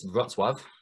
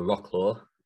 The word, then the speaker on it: Wrocław: It's Wrocław, or Rocklaw, if you want to pronounce it like an Englishman.